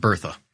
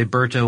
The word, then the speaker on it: Bertha: You could say Berta if you want. I've heard it pronounced that way too, but I'm going to say Bertha. You'll say Berta, whatever. It's tomato, tomato.